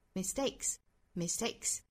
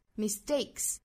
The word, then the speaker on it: mistakes: 那这个词首先我们看到它后面加复数加的这个 s 应该读清辅音的 mistakes, 那清清浊浊，因为 mistake 这个 k 是清辅音，所以后面加的 s 是清辅音的 mistakes。那还有一点呢，就是 s 后面的这个 t 的发音我们可以完全不送气的读成一个类似于我们汉语拼音的这个阿波茨德的的这样的音 mistakes, 也可以稍微送一点点气发成 mistake 也是可以的，